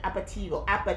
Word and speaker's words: aperitivo: Aptivos. – It's an aptivo. – An aptivo is a, it's like happy hour in Italy. – Jesus. – I'm not pronouncing it right. Aperitivo.